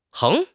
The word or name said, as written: 横